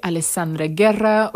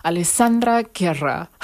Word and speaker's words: Alessandra Guerra: We are live on the air with yours truly, Alessandra Guerra, or in the English pronunciation, Alessandra Guerra.